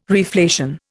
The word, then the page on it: reflation: Indian